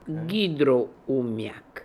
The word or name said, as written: Hydro Oomiak